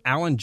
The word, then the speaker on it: Alan: It's Twitter, Alan J. Sanders. That's A-L-A-N, spelled like a first name, not a last name.